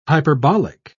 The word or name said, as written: hyperbolic